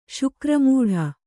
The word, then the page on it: ಶುಕ್ರಮೂಢ: śukra mūḍha